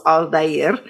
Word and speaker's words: Aldair: It's three syllables, Aldair, because we interviewed him at the academy.